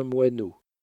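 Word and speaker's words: Moinot: Mon nom s'écrit absolument comme un moineau. M-O-I-N-O-T. N-O-T. Moineau.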